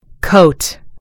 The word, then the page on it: coat: American English